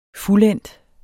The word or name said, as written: fuldendt